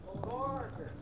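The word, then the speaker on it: Borger: Barger. – How do you spell it there? – O-R-G-E-R. – Oh, Barger. – B-O-R-G-E-R. I still don't like it.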